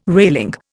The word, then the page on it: railing: Indian